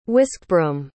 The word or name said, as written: whiskbroom